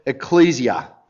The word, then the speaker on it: ecclesia: It's the Greek word ecclesia. Although there was a Greek lady sitting in church this morning who told me that my pronunciation was terrible, that the emphasis should be on the second syllable.